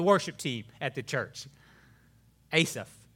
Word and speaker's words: Asaph: Hebrew, it's pronounced a little differently, but for our American ears, Asaph.